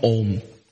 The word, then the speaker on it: Om: In the seventh chapter of the Gita, he says, among all sound vibrations, I am Om. So this word om also comes with three syllables. A, U and Ma.